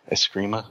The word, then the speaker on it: Eskrima: He has some Eskrima, am I saying that right? – Yeah.